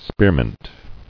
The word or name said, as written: Spearmint